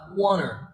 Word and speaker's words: want to: Cái quán tú này, ta sẽ đọc là wanna. Được chưa? Wanna.